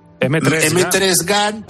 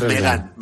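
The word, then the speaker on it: M3GAN: M3 Gun, pero es Megan.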